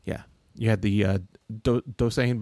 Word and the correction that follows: Doosan: Bears. Dusan Bears. – Doosan Bears.